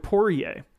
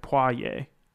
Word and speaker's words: Poirier: Well, so from what I understand, it sounds more like a W. It's like Poirier, but you don't actually say the R.